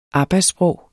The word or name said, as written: arbejdssprog